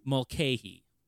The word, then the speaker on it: Mulcahy: Mulcahy. – Mulcahy. – You got it right. You just did a short A instead of a long A.